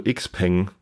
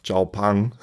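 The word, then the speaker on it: Xiaopeng: Dann kamen wir wieder auf das Auto Xpeng oder eigentlich Xiaopang. – Xiaopang wird das ausgesprochen? – Xiaopang. – Ah, okay. Ich dachte auch immer Xpeng.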